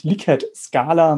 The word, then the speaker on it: Likert: Und obwohl der Urheber Ramses Likert ein ähm, Amerikaner war, heißt es tatsächlich Likert-Skala. Man trifft auch teilweise die Aussprache Likert-Skala an, aber es heißt tatsächlich ähm, Likert.